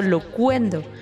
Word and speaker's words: Loquendo: Nada más del nombre, es interesante porque loquendo es la palabra que significa locución y pronunciado locuendo. Por eso es que suena así.